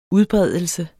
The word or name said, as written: udbredelse